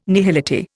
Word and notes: nihility: Indian

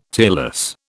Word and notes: tearless: British English

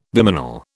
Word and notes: bimanal: British English

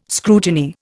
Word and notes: scrutiny: Indian

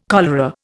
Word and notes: cholera: Indian

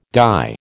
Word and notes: dye: American English

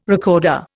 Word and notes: recorder: Indian

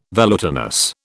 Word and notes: velutinous: British English

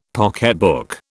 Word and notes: pocketbook: British English

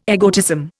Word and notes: egotism: Indian

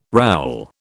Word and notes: rowel: British English

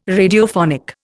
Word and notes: Radiophonic: Indian

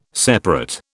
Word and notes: separate: British English

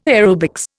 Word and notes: aerobics: Indian

